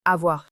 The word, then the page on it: avoir: French